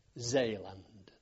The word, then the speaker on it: Zeeland: We sailed from Alexandria on a large troop ship called the New Zealand, spelt in the Dutch fashion, and it was a Dutch ship, N-I-E-U-W, Zeeland, they would pronounce it.